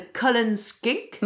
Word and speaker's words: Cullen Skink: J'ai hâte aussi de découvrir les autres plats de Burns Night un peu moins connus, comme le, alors tu me diras si je prononce bien, le Cullen Skink. – Oui, parfait.